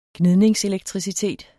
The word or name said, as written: gnidningselektricitet